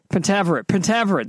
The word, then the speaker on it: Pentaverate: The Pentaverate. I'm not sure how to say it. – Pentaverate, that's it.